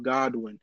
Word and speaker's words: Godwin: It's Tom Godwin. just like that right there. People always mistake my name to be Tom Goodwin, thinking there is a silent O. There's no silent O.